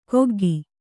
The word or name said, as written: ಕೊಗ್ಗಿ